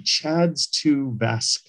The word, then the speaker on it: CHA2DS2-VASC: There's something called the CHADS-2 VASC score. It's spelled CHA2DS2-VASC.